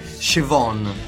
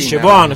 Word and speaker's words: Siobhan: L'attrice, ho scoperto come si pronuncia, Siobhan. – Sì, Siobhan, se avete visto Love and Hate sapete che si pronuncia Siobhan.